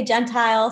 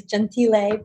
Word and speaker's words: Gentile: I have had so many pronunciations, Gentile, Gentile. I say Gentile, so that's fine. The Italian proper way is Gentile, but yes, it's Cree Gentile.